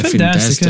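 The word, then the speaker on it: Fntastic: O nome do estúdio é tipo Fantastic, sem o primeiro A, é F-N-Tastic, eu não sei como é a pronúncia, se é Fantastic, é Fantastic.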